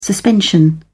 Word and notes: suspension: British English

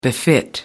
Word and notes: befit: American English

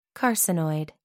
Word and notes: carcinoid: American English

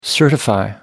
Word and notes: certify: American English

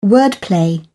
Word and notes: wordplay: British English

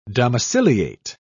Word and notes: domiciliate: British English

